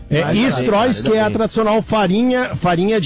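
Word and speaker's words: Streusel: Então é o Stroys que se diz, mas realmente se escreve muito diferente.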